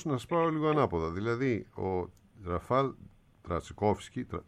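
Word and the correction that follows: Trzaskowski: σωστά το προφέρω, πώς είναι, είναι Τσασκόφσκι, όχι Τρακόφσκι, γιατί όταν είναι ρο ζήτα γίνεται επικρατεί το σύμπτωμα.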